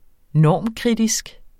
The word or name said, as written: normkritisk